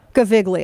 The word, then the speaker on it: Gaviglio: Gavagiolo screamed, thank you, Gaviglio. Is it hard G? – Yeah. – Gaviglio. – Yeah. – So it's like how my family would say singer. Okay.